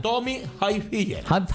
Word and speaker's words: Tommy Hilfiger: Tony Hilfiger. No. – ¿Cómo se pronuncia? – Tommy Hilfiger.